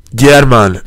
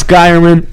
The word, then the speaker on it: Gyerman: Sandor German. That's how you say it in Hungarian. – Just say Alex Geierman.